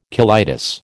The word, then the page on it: colitis: American English